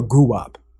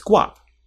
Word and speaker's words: Guwop: Sorry, is it guap or guwap? – It's guap. – It's guap. Why does he spell it like guwap? – What do you mean? – It's spelled, no, have you seen, you know, Gucci Mane was like going to change his name for a minute, but it's spelled G-U-W-O-P. – G-U-W-O-P. – So it sounds like, it looks like guwap. Like mbop, like doo-wop.